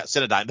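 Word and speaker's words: Cinedigm: Synodigum? I'm not sure how you say it. Synodigum? – Synodigum. – The leading independent. Yeah, Cynodyme. There you go.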